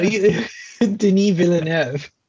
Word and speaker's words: Denis Villeneuve: like Denis Villeneuve? – You call him Villeneuve. No, Denis Villeneuve.